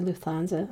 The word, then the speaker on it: Lufthansa: And he said, when I first tried to become a pilot for Lufthansa, I know I'm not saying that correctly. – I think you said it well.